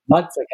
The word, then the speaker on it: Mazzacane: And am I pronouncing your name correctly? Is it Mazzacane? – Yes, it's Mazzacane, but it sounds well. Okay. – I can say Mazzacane. – Yes, yeah, that's perfect.